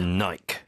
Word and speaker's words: Nike: 미국에서는 이걸 나이키라고 읽는데 영국에서는 이걸 또 다르게 읽지 않습니까? – Nike.